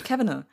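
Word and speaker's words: Cavanagh: Absolut, ein perfekter Zeitverbrenner und ich nehme auch mal an, da der gute alte Terry Kavanagh, GH am Ende, wie spricht man sowas? – Ich meine, es spricht man wirklich Kavanagh.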